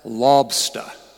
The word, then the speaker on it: lobster: you have to pronounce it as lobster. L-A-W-B-S-T-H-A-H. Lobster.